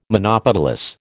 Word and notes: monopetalous: American English

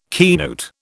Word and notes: keynote: British English

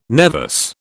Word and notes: nevus: British English

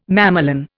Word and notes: mamelon: Indian

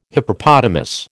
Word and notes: Hippopotamus: American English

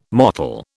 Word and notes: mottle: British English

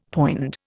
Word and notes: poind: Indian

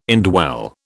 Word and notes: indwell: British English

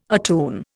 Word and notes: attune: Indian

